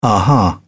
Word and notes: aha: British English

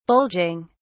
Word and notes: bulging: British English

